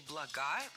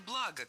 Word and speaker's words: блага: Во-первых, хочу исправить материальные блага на материальные блага, конечно же. Ударение в слове «благо» всегда падает на первый слог. Оно неподвижное.